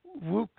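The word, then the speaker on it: Kwouk: And slightly off from that, but a Manchester person, born in Manchester, Bert, I'm not sure how you pronounce his second name, Cato, Ian, Bert Wook is it, that is how it's pronounced.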